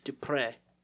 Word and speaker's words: Duprey: My name Dupree, it's supposed to be Dupree.